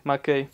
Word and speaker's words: McKay: ¿McKay sí se pronuncia, chicos? – Sí, McKay.